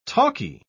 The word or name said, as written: talky